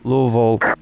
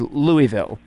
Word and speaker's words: Louisville: You actually pronounced Louisville the proper way. People in Kentucky are supposed to do it. Everyone else across country says Louisville.